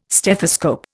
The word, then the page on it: stethoscope: Indian